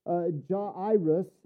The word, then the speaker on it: Jairus: So when we see this man Jairus, and it's really Jairus, is really the way that you would pronounce it.